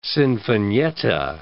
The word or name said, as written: sinfonietta